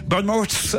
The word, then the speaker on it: Bournemouth: Bournemouth, c'est dans le Dorset pour c'est bien prononcé.